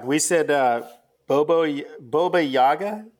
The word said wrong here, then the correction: Baba Yaga: Did I say that right? – Baba Yaga, but yeah, that's Baba what he was Yaga.